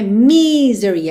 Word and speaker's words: misery: Não é misery, é misery, misery, me, e não me, ok? Misery. Normalmente a sílaba tônica é mesmo a primeira, misery, misery.